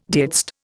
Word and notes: didst: Indian

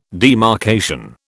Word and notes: demarcation: British English

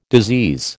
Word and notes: disease: American English

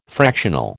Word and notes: fractional: American English

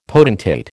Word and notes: potentate: American English

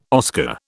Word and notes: Oscar: British English

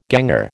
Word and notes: ganger: American English

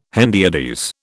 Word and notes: hendiadys: British English

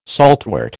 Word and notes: saltwort: American English

